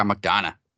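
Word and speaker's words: McDonagh: Is that how you say it? – Oh, yeah, McDonough.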